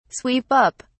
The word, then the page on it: sweep up: British English